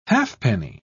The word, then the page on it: halfpenny: British English